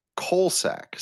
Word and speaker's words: COSALC: You have the website, Colorado State COSALC, but you pronounce it Colsack, COLSAC.